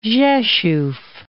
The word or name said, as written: Rzeszow